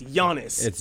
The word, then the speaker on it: Giannis: First of all, if he's going to be your MVP candidate, at least say his name right. It's Giannis. The G is silent.